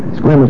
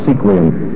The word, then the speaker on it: Sequim: Squim is where I live. My motto is sink or squim. So you have at least the right way to pronounce it, even though most people call it sequim.